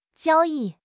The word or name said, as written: 交易